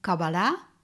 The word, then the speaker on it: Kabbala: En het woord Kabbala, zo wordt het meestal uitgesproken, met een nadruk op de derde lettergreep, dat betekent het ontvangen.